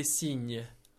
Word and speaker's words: cygne: Yeah, so a swan in French is cygne. – Cygne. – It's kind of... there's a slight... nuance in the pronunciation because it's spelt C-Y-G.